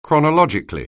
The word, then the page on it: chronologically: anglais